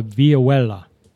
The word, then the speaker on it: vihuela: but by the 15th and 16th century, Spain, there was an instrument called a vihuela. – Ooh, vihuela. – Can you spell that for us? – So that's V-I-H-U-E-L-A, vihuela. – Yeah, I can say that. Vihuela.